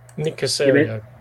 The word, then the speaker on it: Nick Caserio: Nick Caserio. – Caserio.